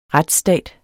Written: retsstat

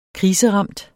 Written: kriseramt

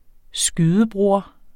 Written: skydebroder

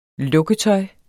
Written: lukketøj

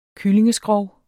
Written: kyllingeskrog

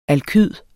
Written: alkyd

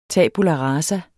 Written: tabula

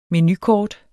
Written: menukort

